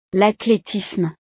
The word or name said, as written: l'athletisme